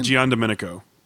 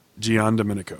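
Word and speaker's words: Giandomenico: Carmine D. Giando Mencia. Did I say that right? Giando Mencia? Is it like Italian? – Gian Domenico. – What? – Gian Domenico.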